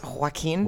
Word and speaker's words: Joaquin: It's actually pronounced Joaquin. Joaquin.